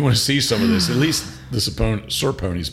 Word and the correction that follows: Saponi's: pizza. Mm-hmm. Am I saying it right? – Saponi's. – Okay, Saponi's.